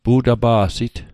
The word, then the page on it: pu'tapa'sit: Mi'gmaq